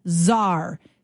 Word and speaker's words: czar: The other word that starts with C is czar, C-Z-A-R. There's tons of talk in our province and our country about getting a border czar, a fentanyl czar. And it's got the silent letter, right? C is silent.